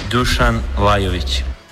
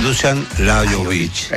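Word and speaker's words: Dušan Lajović: Dusan Lajović. – Dusan Lajović. – Exactamente. – Lajović. Aparece como una I, la J como I.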